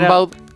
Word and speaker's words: Rimbaud: Claro, se pronuncia Gambot, pero es claro. – Rimbaud.